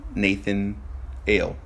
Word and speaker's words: Nathanael: It's like Nathanael, but just Nathan Ale.